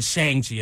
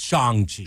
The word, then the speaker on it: Shang-Chi: So are we saying Shang-Chi right? – It's you said it right. – Shang. – He said Shang-Chi. It's Shang-Chi.